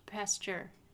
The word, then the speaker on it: Pasteur: Pasteur. Like Louis Pasteur.